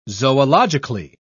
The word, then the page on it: zoologically: British English